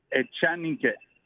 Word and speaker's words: Echanique: Echanique.